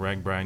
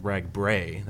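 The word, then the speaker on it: RAGBRAI: a lot of columns about bicycling and about RagBri, and you wrote a book about, you know, RagBri, or as you like to say, it should be called RagBray. That's the proper pronunciation.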